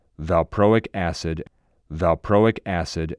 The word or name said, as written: Valproic Acid